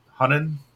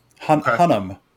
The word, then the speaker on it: Hunnam: Hunan? – Hunnam.